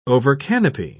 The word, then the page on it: overcanopy: British English